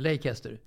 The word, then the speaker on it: Leicester: Som mig då, utan Leicester.